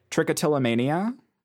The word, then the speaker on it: trichotillomania: Trichotillomania? – Yeah.